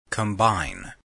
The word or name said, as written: combine